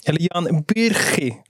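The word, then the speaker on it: Jan Bürki: Jan Byrki, som Matringen ska säga. Visst var det så? – Exakt, det är bra uttal där också. – Ja. – Schweiziskt uttal, ja.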